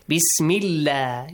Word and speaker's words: Bismillah: Bismillah, you pronounce it lightly.